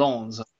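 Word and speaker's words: Lens: Promoted Len, I believe is how you pronounce it. – What? Lawns. Lawns. Lawns. Okay, sorry.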